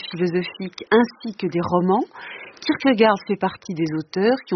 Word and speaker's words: Kierkegaard: C'est un Kierkegaard.